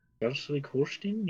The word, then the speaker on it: Schleswig-Holstein: Schleswig-Holstein. – Schleswig-Holstein, yeah.